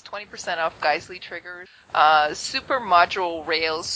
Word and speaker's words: Geissele: Gizel. – Geissele.